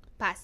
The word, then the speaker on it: Paz: Paz.